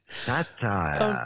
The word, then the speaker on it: satyr: Or is it a satyr? Satyr? How do you pronounce that? S-A-T-Y-R. – A or B, Damo? – Could it be a centaur? Could it be a centaur? – Don't say centaur. Satire.